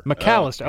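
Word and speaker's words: Macalester: McAllister, oh, okay. – Yeah, that's how you say it. – Well, it's M-A-C, not M-C.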